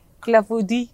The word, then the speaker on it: clafoutis: Berättar du hur det här uttalas då? – Alltså jag har ingen aning. – Nej. – Du är... – Säg! – Clafoutis. – Ja, men faktiskt, clafoutis.